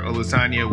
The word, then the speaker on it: Olusanya: So Dr. Olusanya, welcome to the show.